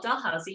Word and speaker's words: Dalhousie: So first of all, you crushed it. It is called Dalhousie University.